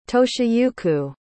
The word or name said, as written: toshiyuki